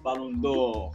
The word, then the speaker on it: Ballon d'Or: No, be Ballon d'Or, it is pronounced Ballon d'Or.